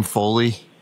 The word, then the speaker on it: Foley: Yes, my name is William Foley, F-O-L-E-Y.